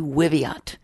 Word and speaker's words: Wiviot: Marty Wiviot. It rhymes with Marriott.